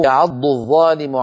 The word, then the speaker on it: يعض الظالم: فإذا أيضا لا بد لنا بارك الله فيكم أن نبين صوت الضاد من صوت الظاء، فلا يقول أحدنا يعض الظالم، يعض الظالم، نكون قد نطقنا ظائين وهذا خطأ.